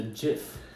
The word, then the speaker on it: GIF: The GIF. – Ah, and how is that spelled? – G-I-F. – Good job. – But pronounced J-I-F.